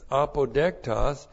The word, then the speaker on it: apodektos: And then there is apodektos. A-P-O-D-E-K-T-O-S, apodektos, and that means pleasing, apodektos.